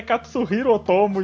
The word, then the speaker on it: Katsuhiro Otomo: E só depois que eu fui aprender que a pronúncia correta é Katsuhiro Otomo e não tem um S ali bom, onde eu achava.